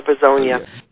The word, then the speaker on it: Bozena: Well, I think it's spelled on the record B-O-Z-E-N-A. – Yes. Is that correct? – Well, that's what they put down on it. I that must be the correct word. But we pronounced it Kayla Bozonia.